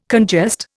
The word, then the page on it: congest: Indian